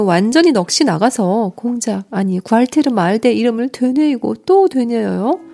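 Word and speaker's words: Gualtier: 이 구알테르 말데의 구알테르 발음을 놓고 설전이 있다고 하네요. 여기선 프랑스의 가난한 대학생이다 보니 구알테르로 발음하는 게 맞는 것 같아요.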